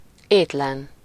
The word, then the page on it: hungry: /ˈhʌŋ.ɡɹi/; English